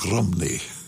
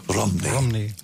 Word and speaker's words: Rhymney: That's how it's spelt. R-H-Y-M-N-E-Y. But there they pronounce it more like Rimini. Rimini.